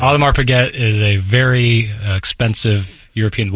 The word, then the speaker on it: Audemars Piguet: Audemars Piguet. Paget. I'm sorry. Alomar's Paget. – Thank you for the pronunciation correction.